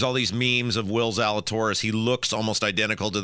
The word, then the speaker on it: Zalatoris: Okay, so there was this quote that I wanted to share with you, and how do you say his name? Will Zalatoris. – Yes, Zalatoris. Yes.